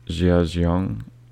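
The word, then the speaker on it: Jia Jiang: So the next one is also a TED Talk, and it's by Jia Xiong, I think. If I'm pronouncing it wrong, that's my bad, but it's spelled J-I-A space J-I-A-N-G, and it's called What I Learned from 100 Days of Rejection.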